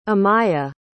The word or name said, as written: amiyah